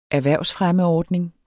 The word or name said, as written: erhvervsfremmeordning